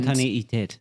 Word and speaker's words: Spontaneität: Spontaneität. – Das ah. EI wird also gar nicht wie I in Frühstücksei ausgesprochen.